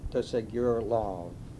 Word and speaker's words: de Segur Lauve: My name is Henry de Segur Lauve. It's spelled D-E-S-E-G-U-R-L-A-U-V-E.